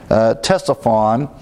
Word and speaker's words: Ctesiphon: The C is silent here, so you pronounce it beginning with the T, Ctesiphon.